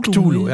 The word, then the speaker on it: Cthulhu: Cthulhu, ja. Schreibt sich C-T-H-U-L-H-U. Spricht sich, soweit ich weiß, unsere Horror, äh, unsere Science-Fiction-Horror-Mystery-Fans können sich gerne melden, wenn ich es falsch mache.